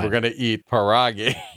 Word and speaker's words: pierogi: And the first thing we learned was how to pronounce this name. And it's called pierogi.